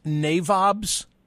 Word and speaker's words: NAVOBS: I guess it's pronounced NAVOBS. – Yes.